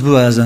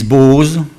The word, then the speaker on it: Boaz: Alors, moi, je prononce Boaz, hein. D'autres traductions disent Boaz. Bon, c'est Boaz.